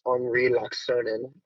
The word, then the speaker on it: Henri Laaksonen: I'm also going to correct you on your pronunciation. It's Henri Laaksonen.